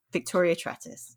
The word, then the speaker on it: Victoria Trettis: And also, I'm really impressed that you got the pronunciation of my name right at the beginning. There was like no hesitation, and you weren't like, is it Trettis? I'm not sure. Victoria Trettis. Love it.